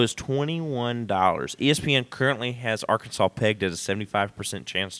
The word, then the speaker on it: Arkansas: Moving on, Liberty and Arkansas. – It's pronounced Arkansas.